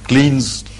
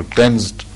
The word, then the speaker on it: cleansed: Every man is a born sinner, and he must be somehow forgiven and cleansed of his sins, or cleansed. Cleansed is the pronunciation.